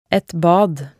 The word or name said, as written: et bad